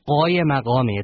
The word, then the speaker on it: Qaimmaqami: Well, the Q's in the name are actually guttural uhs. So it's spelled Q-A-I-M-M-A-Q-A-M-I.